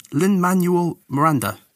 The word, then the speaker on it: Lin-Manuel Miranda: Lin-Manuel Miranda.